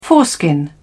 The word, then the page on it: foreskin: British English